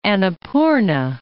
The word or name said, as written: Annapurna